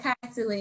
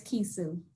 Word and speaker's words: Kisu: You said Kaisu, and it is Kisu.